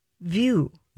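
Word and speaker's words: Vue: Her view. – Exactly. – V- V-U-E.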